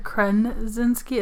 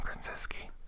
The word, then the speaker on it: Krasinski: A Quiet Place is written and directed by John Krasinski. – You said it wrong again. – Krasinski. – It's not Krasinski. – Krasinski. – That is, what a bully. Don't bully him. – I'm saying Krasinski.